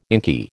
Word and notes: inky: American English

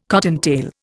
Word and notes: cottontail: Indian

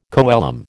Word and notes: coelom: American English

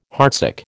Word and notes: heartsick: American English

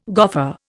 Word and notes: goffer: Indian

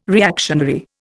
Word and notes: reactionary: Indian

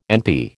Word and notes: NP: American English

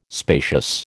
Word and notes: spacious: American English

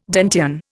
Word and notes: Dantean: Indian

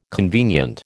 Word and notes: convenient: American English

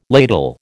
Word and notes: ladle: American English

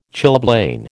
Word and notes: chilblain: American English

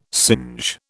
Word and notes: singe: British English

